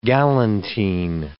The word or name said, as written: galantine